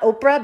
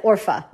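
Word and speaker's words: Orpah: Like Ruth said to Orpha, or I mispronounce her name sometimes. It's not Oprah, but it's like Orpha. There we go.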